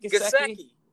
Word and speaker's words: Gesicki: It is Mike Gesicki. I thought it was Gillespie for a second, and then I realized there's no L. Mike Gesicki had a great week for Jackson.